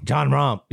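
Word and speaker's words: Jon Rahm: John Rahm. You got to say it like that.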